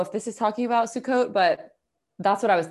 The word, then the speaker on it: Sukkot: Wait, I want to see how to pronounce it, but let's hear it. Did you hear that? – No. – Oh, Sukkot. So Sukkot is a holiday, Sukkot, which it's a holiday that you'll see being celebrated like in Hasidic neighborhoods in New York.